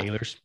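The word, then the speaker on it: Eilers: 7, Sally Ehlers, is that how you say it? Yeah. – Ehlers, yeah.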